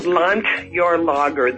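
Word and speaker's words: Landjahrlager: Well, you know, the school, it's called Landjarlager. The D is pronounced as a T.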